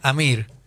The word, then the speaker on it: Amr: Amr Mokhtar, que lo trajo de Egipto. Amr Mokhtar, el asistente de Diego. – No, se pronuncia Amir. Pero le falta la I.